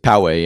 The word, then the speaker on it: Poway: What is it, Poway? How do you say that? – Poway, yep.